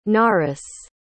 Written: naras

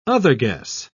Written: otherguess

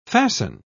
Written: fasten

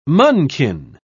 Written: munchkin

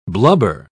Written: blubber

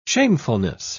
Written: shamefulness

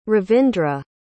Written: ravindra

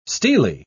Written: stele

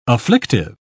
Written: afflictive

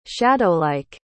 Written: shadowlike

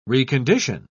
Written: recondition

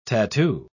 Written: tattoo

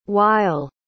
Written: weill

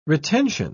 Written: retention

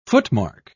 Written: footmark

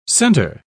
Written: centre

